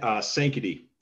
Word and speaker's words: Sankaty: So I think when I heard that you guys were, you know, at, correct me if I'm wrong, Sankaty, is that how it's pronounced? – Sankaty. Sankaty.